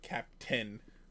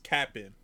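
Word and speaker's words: Cap'n Crunch: First off, it's Captain Crunch, all right? Say it right, or don't say it at all. Not Captain. It's Cap'n.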